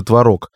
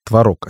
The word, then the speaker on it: творог: Он же творог. До сих пор некоторые нормативные словари говорят, что правильно говорить творог, а не творог.